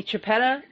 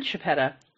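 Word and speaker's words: Chiappetta: I publish under the name Ann Chepeta. And my last name is spelled C-H-I-A-P-P-E-T-T-A. I like to say include my name by spelling it with two P's and two T's.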